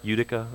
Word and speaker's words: Utica: Did I say that right? Utica? Okay, I always look at it, think it's Utica, but I know better.